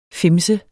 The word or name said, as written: fimset